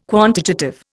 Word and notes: quantitative: Indian

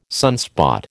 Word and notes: sunspot: American English

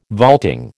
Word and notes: vaulting: American English

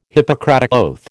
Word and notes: Hippocratic oath: American English

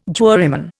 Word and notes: juryman: Indian

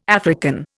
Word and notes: African: Indian

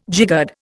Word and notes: jiggered: Indian

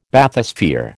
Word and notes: Bathysphere: American English

